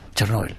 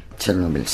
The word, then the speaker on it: Chernóbil: Chernóbil. – Chernóbil, sí.